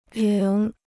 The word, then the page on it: 仍: réng